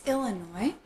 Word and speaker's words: Illinois: It's Illinois. The S is the silent S.